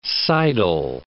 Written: sidle

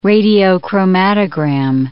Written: radiochromatogram

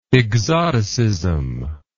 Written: exoticist

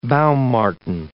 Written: baum marten

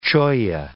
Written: cholla

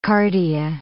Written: cardia

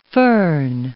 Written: fohn

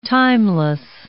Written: timeless

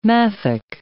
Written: mafic

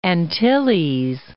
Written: Antilles

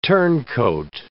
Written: turncoat

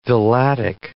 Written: villatic

